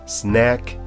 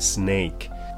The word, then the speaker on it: snack: 注意哦，不要把它发音成蛇哦，snake，那这样会吓坏人哦。 snack and snake 可以要区分它的不同的地方。